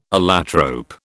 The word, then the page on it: allotrope: British English